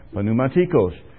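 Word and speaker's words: Pneumatikos (πνευματικός): In the Greek you pronounce the P, in the English you don't. Pneumatikos.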